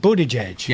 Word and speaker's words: Buttigieg: Oh, Mayor Pete Buttigieg.